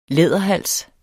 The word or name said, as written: læderhals